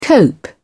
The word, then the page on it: cope: British English